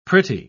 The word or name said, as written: pretty